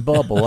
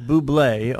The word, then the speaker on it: Bublé: It's a joke now that he's saying Bubble. Understand, he understands that his name is Bublé, okay, but it's spelled Bubble. – In Arkansas, it's pronounced Bubble. – All right. – In Canada, it's Bublé.